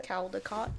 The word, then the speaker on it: Caldecott: I'm always afraid that I'll get stuck in a tunnel, especially like the Caldecott tunnel. Is that how you say it? – Caldecott.